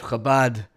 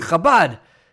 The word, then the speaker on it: Chabad: and somebody said, "How do you say the ch in Chabad?" And he and he said, "It's very simple. You just have to say it as if you have uh, cream cheese stuck in your throat."